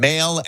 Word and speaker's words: Mahle: Mahle, M-A-H-L-E.